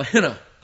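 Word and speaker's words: Henna (ἵνα): And in verse 14, we have what we call a henna clause. Henna. I, Yota, with a rough breathing mark, Nun, Alpha. Henna is pronounced, and it means in order that.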